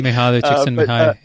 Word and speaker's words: Mihaly Csikszentmihalyi: There's a professor named Mihaly Csikszentmihalyi, which it took me a long time to figure out how to pronounce that correctly. – Mihaly Csikszentmihalyi, yep.